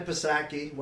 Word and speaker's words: Psaki: Anyways, Vivek Murthy, Surgeon General, followed by Jen Psaki, White House spokesperson. – Is it Psaki or Psaki? Whatever. I think it's a silent P, brother.